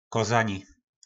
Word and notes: kozani: po našimu

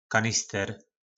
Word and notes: kanister: po našimu

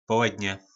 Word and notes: poledne: po nasimu

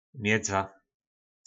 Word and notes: medza: po našimu

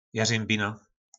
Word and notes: jarymbina: po našimu

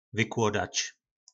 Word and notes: vyklodac: po našimu

